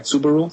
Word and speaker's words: Subaru: Subaru? – Subaru.